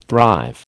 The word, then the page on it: thrive: American English